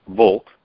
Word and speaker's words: Volk: Volk, like folk. We get our word folk from Volk. Folk means people, and Reich, we spell it R-I-G-H-T, they spelled it R-I-H-T, but it's the same word, and it means duty.